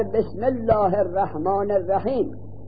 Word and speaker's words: بسم: در بسم الله الرحمن الرحیم اون الف وصل به اسمه، ای الف اسم در تلفظ حذف شد، بسمه همزه حذف شد، در کتابت هم حذف شد، اما در اقرأ بسم ربک، قرآن را ببین، اقرأ بسم ربک الف را در کتابت حذف نمی کنن، فقط در تلفظ حذف می کنن.